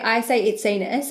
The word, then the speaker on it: Itsenis: And then like I say Itsenis, so that's how I say it.